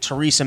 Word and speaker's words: Theresa: Are you sure it's not pronounced Theresa? I wasn't sure. Did I lose you there? – Theresa. – No, I'm here. Theresa. I was kidding. It's clearly not Theresa. So anyways.